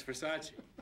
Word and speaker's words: Versace: It's Versace. – What? – Versace. It's pronounced Versace.